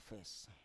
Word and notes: fes: Biak

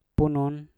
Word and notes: punun: mpur

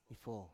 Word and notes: ifo: Biak

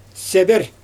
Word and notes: seber: Xyzyl

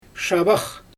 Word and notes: ʃabax: Xyzyl

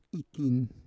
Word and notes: ikin: Roon

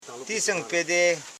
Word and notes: singpe: sing.pe; Koro

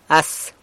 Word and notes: as: San Lucas Quiaviní

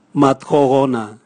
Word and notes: mathohona: Raga

